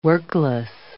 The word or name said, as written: workless